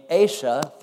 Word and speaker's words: Asa: But then he had a son named Asa. Finally, we got one we could pronounce, A-S-A, Asa. That's pretty good.